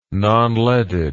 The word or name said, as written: nonleaded